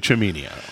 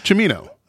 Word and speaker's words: Cimino: It's actually Cimino. Cimino. – It's Cimino.